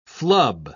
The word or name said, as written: flub